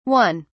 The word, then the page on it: 1: British English